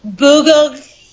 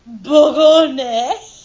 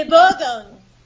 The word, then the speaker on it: Bourgogne: Duchess de Bourgogne. – No. I know you want to pronounce that second G, but you don't. Think of cologne. – Duchesse de Bogone.